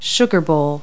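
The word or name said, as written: sugar bowl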